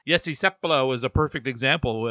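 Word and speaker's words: Seppälä: I played 1 year here with the Edmonton Oil Kings and asked him how you pronounce your name, because I've always been told Finnish names, accents on the first syllable, and you kind of say it quickly after that. So it was Seppala.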